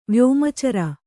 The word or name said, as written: ವ್ಯೋಮಚರ